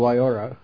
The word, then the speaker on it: Waiora: The company is called Wyora. That's spelled W-A-I-O-R-A.